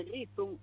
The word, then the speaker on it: Reason: Mira, se si tuveras, hai a canción de Rizón, pero de Rizón, Reason, se escribe, sí.